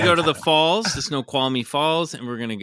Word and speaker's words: Snoqualmie: Snoqualmie, I believe is how you say it. – Snoqualmie. Wow. – It's S-N-O-Q-U-A-L-M-I-E.